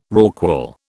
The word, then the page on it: rorqual: British English